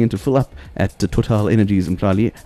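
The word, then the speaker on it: TotalEnergies: It's great to have you in the house and to be talking about what's hot and happening down at Total Energies. Well, see, this is the thing. People, South Africans call it Total, but if you're doing the French pronunciation, it's Total.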